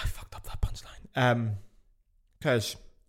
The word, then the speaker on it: Kölsch: This is Kölsch. This is the local beer in Cologne. Kölsch, it's pronounced like Köln, O with an umlaut, is Sorry, I don't know what it is, but it'll be gone soon.